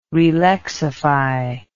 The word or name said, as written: relexify